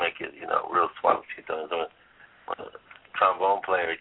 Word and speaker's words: Suavecito: Okay, the next song, and I'm hopefully I pronounced this correctly, Suavecito? – Suavecito, yes.